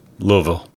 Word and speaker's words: Louisville: Louisville. – You have to say it like you're throwing away three or four of the letters. Louisville.